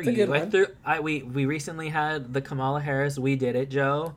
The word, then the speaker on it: Kamala: It's Kamala, like a Kamala. Jesus Christ. – She's like a llama, but a kuh.